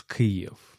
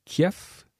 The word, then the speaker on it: Kyiv: Et d'ailleurs, la ville, la capitale de l'Ukraine, se prononce Kyiv. Donc, c'est parfois qu'on entend toujours des journalistes francophones qu'ils parlent de Kiev ou Kiev. Kiev, c'est plutôt une phonétique russe. Et en ukrainien, on dit Kyiv.